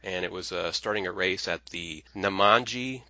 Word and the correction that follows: Nemadji: Trail Rally. Did I say that right? – Yeah, Nemadji. Yep, Lake Nemadji.